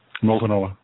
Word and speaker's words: Multnomah: Multnomah.